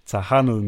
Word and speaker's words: Tejano: And she was known as the queen of Tejano music, which is a Mexican-American Tejano music. I think I'm saying that right. – Right. – I'm guessing it's a silent J.